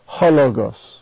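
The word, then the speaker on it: hologos (ὁ λόγος): That is, it goes so closely with the word that follows so as to have no accent of its own. Its pronunciation is hologos.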